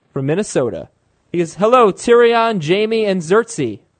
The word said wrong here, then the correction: Cersei: Or Cersei. – Cersei.